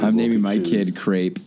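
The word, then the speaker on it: crêpe: My name is Crepe. – It rhymes with rape and grape.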